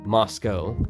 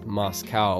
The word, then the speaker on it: Moscow: Born in Moscow. Often mispronounced as Moscow, like I live in Russia.